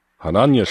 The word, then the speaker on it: Hananja: En Hanania toen, Hanania spreek je het geloof ik uit, H-A-N-A-N-J-A.